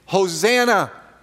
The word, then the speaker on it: Hosanna: Hosanna is the Greek rendering, the Greek pronunciation of the original Hebrew, Hoshi'ana, Hoshi'ana, save us, we pray, O Lord, which in Greek is Hosanna, and we retain it. Hosanna.